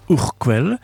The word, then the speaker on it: Urquell: On écrit Urkel, mais on prononce Urquell, qui est brassée à Pilsen, dont le nom a donné le mot Pils.